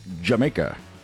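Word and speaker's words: D'yer Mak'er: I know it's spelled like Dyer Maker, but it's pronounced Jamaica.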